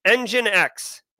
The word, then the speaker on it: Nginx: Again, Nginx, okay. Nginx is how you pronounce it. I've always said Nginx, but you know, this is what happens when you just read things instead of hearing them.